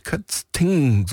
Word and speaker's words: Kötzting: Und da hat die Nina aus Bad Kötzing geschrieben. Kötzting, so muss ich es richtig aussprechen. Das ist in oberen Bayerischen Wald und sie schreibt, wir möchten uns bei Heinz für den unvergesslichen Familienurlaub auf der Insel Rügen bedanken.